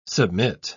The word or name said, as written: submit